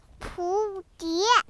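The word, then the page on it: 蝴蝶: 普通话; 儿童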